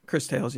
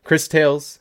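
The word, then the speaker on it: Cris Tales: Chris Tales, yeah. – Yeah, all right. Chris Tales.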